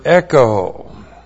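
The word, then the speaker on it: echo (ἔχω): And then we have the verb echo. E-C-H long O. Echo.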